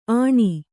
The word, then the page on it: ಆಣಿ: āṇi